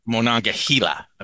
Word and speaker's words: Monongahela: Monongahela, I believe, is the actual pronunciation.